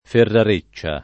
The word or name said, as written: ferrareccia